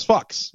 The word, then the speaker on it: Fuchs: No, I think the CH is like a K, right? – So it's Fox.